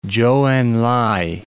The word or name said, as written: Chou En-lai